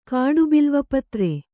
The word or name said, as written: ಕಾಡುಬಿಲ್ವಪತ್ರೆ